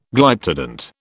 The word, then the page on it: Glyptodont: British English